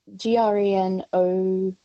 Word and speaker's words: Grenouille: Is it Grenouille? I think if it's the French word, it's Grenouille. – Grenouille, thank you. Yeah. Because it is the French word, but not pronounced very well.